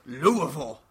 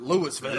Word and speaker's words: Louisville: And it's pronounced Louisville. Not Louisville. – Louisville. – Louisville. – Like the slugger.